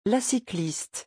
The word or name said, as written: la cycliste